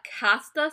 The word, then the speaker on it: casta: And so Mexico had a casta system, which, like, for us white folk, like, it's the caste system, but it's pronounced casta in Mexico.